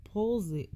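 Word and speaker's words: Palissy: So there's this dude named Bernard Palsy. Policy? How do you say that? – Policy.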